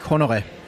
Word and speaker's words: Honoré: So the correct pronunciation of his name in Danish would be Mikkel Frølich Honoré.